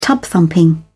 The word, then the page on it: tub thumping: British English